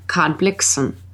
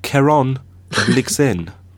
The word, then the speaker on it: Blixen: Now, number 10 is an interesting one for me because it references a story by Karen Blixen, or as they call her in Denmark, Karen Blixen. I take it that you know Karen Blixen. – Yes, but I pronounce it Karen Blixen.